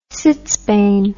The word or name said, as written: sitzbein